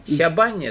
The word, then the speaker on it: щабань: Щабань это вот щабань оно произносится.